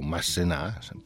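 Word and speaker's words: Masséna: Il generale Massena, o Massena, per pronunciarla alla francese,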